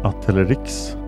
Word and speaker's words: Atelerix: Ett släkte är Atelerexis. Förekommer huvudsakligen i Afrika. Och i nära släkt med arterna i Erinaceus. Bland Atelerix, så uttalas det, förekommer afrikansk pygmeigelkott.